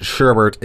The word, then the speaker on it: sherbet: Sherbert with an additional R in the second syllable and pronounced sherbert is less commonly used, but apparently also counts.